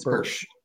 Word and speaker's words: Bursch: The author, John Bursch, am I pronouncing your name correctly? Is it Bursch? – Yes, it's Bursch.